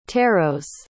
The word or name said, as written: tarots